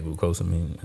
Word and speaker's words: glucosamine: And so, like glutamine and stuff in it? – It has glucosamine and... – Yeah, glucosamine.